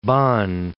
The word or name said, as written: Bonn